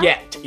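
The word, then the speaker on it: Collet-Serra: Coyet.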